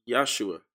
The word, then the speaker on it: Yahshua: The I is used as a consonant. So that's how it's pronounced. So, but the J would come to replace it. And now we have, it would still be pronounced Yahshua.